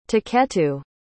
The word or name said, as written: tuketu